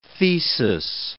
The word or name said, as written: thesis